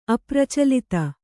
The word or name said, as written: ಅಪ್ರಚಲಿತ